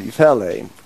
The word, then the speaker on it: Ifele: Say that again, Father, for me. – Ifele. – Okay, I got to get my syllables right and my emphasis in the right places. Ifele.